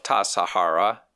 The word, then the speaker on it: Tassajara: The last syllable of Tassajara is Ra.